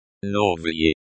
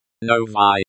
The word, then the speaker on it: Новые: Новые, new. Plural, feminine form of the adjective. – Новые. – Новые. The Y at the end of the word is pronounced like the I in the word bit.